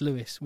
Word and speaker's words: Lewis: We only found out this week it's not Louis, it's Lewis. We've been saying it wrong.